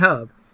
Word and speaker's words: herb: There's a silent H in some American, like honor and herb, and there's a couple more, like hour.